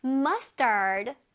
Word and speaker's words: mustard: 머스터드도 영어로 머스터드예요. 그렇지만 발음은 조금 달랐지? Mustard.